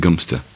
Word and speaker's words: Godmanchester: Godmanchester, and God knows why, is pronounced Gumster.